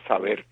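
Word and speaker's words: SABR: Y el nombre de las letras dicen Saber sin E, pero se pronuncia Saber, porque ellos saben que Saber quiere decir conocer en español.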